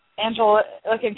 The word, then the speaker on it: Anglican: And Angelican, I know I'm saying that wrong. Anglican Communion, sorry, as well as the Lutheran Church.